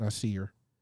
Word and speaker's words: Nasir: Nasir.